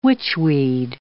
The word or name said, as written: witchweed